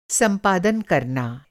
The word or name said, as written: संपादन